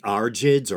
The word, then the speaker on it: Argeads: And so the Kingdom of Macedonia was founded around the 8th century BCE by the Argids or Argeads. I heard both pronunciations, but the pronunciation I heard more often was Argid, and it's spelled A-R-G-E-A-D-S.